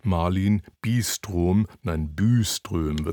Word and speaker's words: Malin Byström: Eine tolle Sängerin, jedenfalls eine sehr gute, ist die schwedische Sopranistin Malin Byström wird sie ausgesprochen,